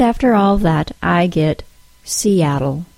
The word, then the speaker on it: Seattle: That stop I added to the word was the glottal stop, and it's the most common problem I hear with non-native speakers when words have adjacent vowel sounds. Specifically, the long E sound links to the short A sound in the word Seattle.